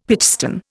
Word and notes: pitchstone: Indian

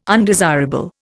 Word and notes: undesirable: Indian

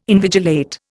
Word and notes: invigilate: Indian

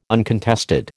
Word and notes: uncontested: American English

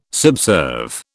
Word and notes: subserve: British English

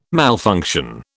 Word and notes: malfunction: British English

